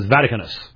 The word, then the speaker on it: Vaticanus: Now you can see why I misunderstood what he said, because he didn't even pronounce Vaticanus correctly. It was Vaticanus.